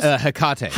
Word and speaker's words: Hecate: But I was like trying to find out because it was also one of the, I believe it's Hectate? Is that how you pronounce it? – Probably. – the goddess? – The goddess, the witch? – The yes, witch the witch goddess? – Hecate. – Hecate? – Yeah.